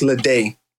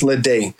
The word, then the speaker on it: Ledet: So my last name is pronounced Ledet because the E-T in French is pronounced A. – Right. – So it's Ledet, but a lot of people, some people spell it L-E-D-A-Y, how it sounds.